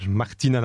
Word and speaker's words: Martina: Mariana Navratilova. – Martina Navratilova, effectivement. Voilà pour le prénom. Écorché, mais c'est pas notre époque.